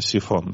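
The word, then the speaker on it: Psiphon: Из самых любимых и самых популярных, наверное, это программа VPN, такая бесплатная программа, которая называется Сайфон. Она пишется Псифон. Читается, естественно, Сифон, да?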